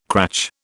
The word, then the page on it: cratch: British English